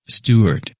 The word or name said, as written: Stewart